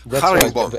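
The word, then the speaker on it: garingboom: So the G is pronounced like a Hebrew CH. – Haringbaum. Haringbaum. Very guttural.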